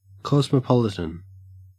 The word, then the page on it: cosmopolitan: Australian English